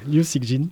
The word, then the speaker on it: Liu Cixin: Liu Cixin.